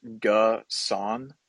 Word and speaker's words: Gusan: He says it's pronounced Gusan, so I'm gonna roll with him for now.